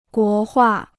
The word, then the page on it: 国画: guó huà